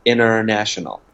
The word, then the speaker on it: international: 嗯，那也有彻底的省略，所以比如美美式的发音不喜欢发 international 的 t 的音，经常会省略，就发 international。